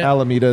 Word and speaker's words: Alameda: Oh, everyone's making fun of me for how I said Alameda. How do I say it? – Alameda is fine.